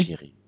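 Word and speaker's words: Jiří: Iri Pavlenka will ich noch rein, reinschmeißen. Oder wie, wie, wie spricht man den aus? – Schiri. – Schiri? – Schiri?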